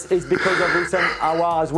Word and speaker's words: Houssem Aouar: Who are you talking about? – Houssam. – I'm sorry. I shouldn't have. That was really mean. Houssam Aouar. – Yeah, Houssam Aouar.